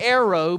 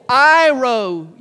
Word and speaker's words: airo: It is spelled A-I-R-O. It looks like arrow, but it's pronounced airo.